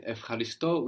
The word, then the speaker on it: ευχαριστώ: So in ευχαριστώ, we have EU pronounced F, as in ευφορία, euphoria.